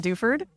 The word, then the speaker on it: Duford: I pronounce it Duford, but that's okay. My sister pronounces it Duford because it's her last name too. Anyways, long story. I'm Daila Duford.